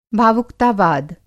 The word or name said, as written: भावुकतावाद